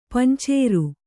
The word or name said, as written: ಪಂಚಾರ